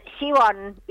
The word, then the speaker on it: Siobhan: Siobhan. – ¿Con como con CH, Siobhan? – Claro, SHI, así se pronuncia, SHI, B corta, O N.